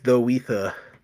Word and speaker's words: The Weether: Though my screen name is The Weether. I guess now it has an official pronunciation. Mm-hmm. It's like if you wrote The Weather, but with the word ether in it mm-hmm.